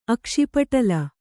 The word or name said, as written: ಅಕ್ಷಿಪಟಲ